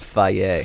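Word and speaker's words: Fage: Fuck Faget. – You guys should do a fake sponsorship every single time until it comes true. – Oh, that's good. – You know? – It's actually pronounced Faye.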